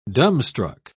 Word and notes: dumbstruck: British English

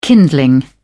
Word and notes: kindling: British English

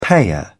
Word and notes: payer: British English

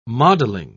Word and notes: modeling: British English

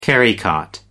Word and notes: carrycot: American English

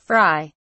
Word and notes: fri: British English

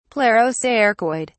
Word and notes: plerocercoid: British English

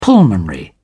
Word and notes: pulmonary: British English

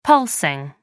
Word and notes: pulsing: American English

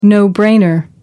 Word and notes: no-brainer: American English

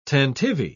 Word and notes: tantivy: British English

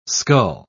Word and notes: scull: British English

